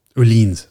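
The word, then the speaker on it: Öhlins: I mean, Ohlins, sorry. Shouldn't we pronounce that correctly? It's like someone saying Bilstein. – I mean, but that's... – You don't speak IKEA? – I don't, yeah. Swedish. – Swedish? – Swedish. – Is that delicious Swedish? Mm. Is that those meatballs that you get in IKEA? Yeah, Ohlins apparently is the correct pronunciation.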